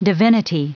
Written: divinity

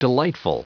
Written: delightful

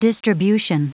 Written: distribution

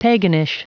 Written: paganish

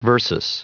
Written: versus

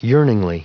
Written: yearningly

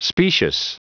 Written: specious

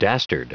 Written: dastard

